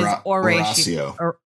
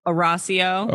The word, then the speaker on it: Horacio: Yes, it is Horacio. Horacio. – Horacio. Horacio. – Horacio. – Okay. – Yeah, because in Spanish, the H is silent.